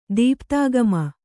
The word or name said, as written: ದೀಪ್ತಾಗಮ